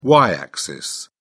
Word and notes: y-axis: British English

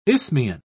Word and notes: isthmian: British English

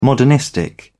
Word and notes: modernistic: British English

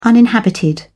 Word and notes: uninhabited: British English

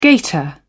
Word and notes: gator: British English

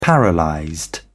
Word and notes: paralysed: British English

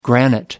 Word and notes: granite: American English

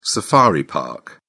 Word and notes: safari park: British English

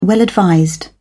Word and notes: well-advised: British English

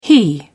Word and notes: he: British English

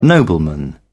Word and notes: nobleman: British English

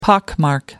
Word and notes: pockmark: American English

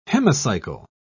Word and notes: hemicycle: British English

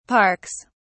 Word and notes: parks: British English

